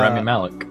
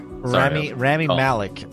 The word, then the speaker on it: Rami Malek: I really hope that Rami Malek, is that how you say it? – Yeah, Rami Malek. – Rami Malek.